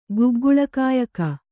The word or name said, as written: ಗುಗ್ಗುಳಕಾಯಕ